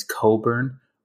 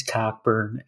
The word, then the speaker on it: Cockburn: Kofi, everybody says his name is Coburn.